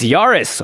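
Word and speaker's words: Yarus: It's Yaris, Roar of the Old Gods. I think that's how you say it. – That sounds like the right pronunciation.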